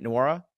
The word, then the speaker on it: Nwora: Nwora? – Yeah.